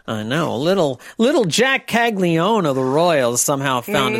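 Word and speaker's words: Caglianone: And then outfielder Jack Caglione of the Royals. Caglione. Okay. The first N is silent then.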